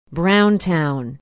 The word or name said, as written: browntown